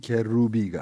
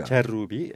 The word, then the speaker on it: Kerrubi: Mirsey Musavi ile Mehdi Kerrubi. – Kerrubi, evet.